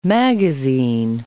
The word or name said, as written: magazine